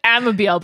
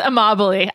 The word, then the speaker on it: Amabile: I thought it was like Amabile, but I think it's Amabile.